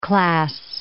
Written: class

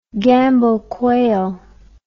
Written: Gambel quail

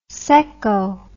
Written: secko

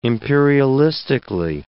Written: imperialistically

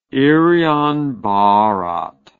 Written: irian barat

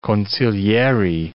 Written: consiglieri

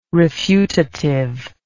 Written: refutative

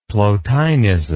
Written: plotinism

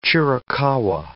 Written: Chiricahua

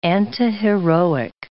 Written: antiheroic

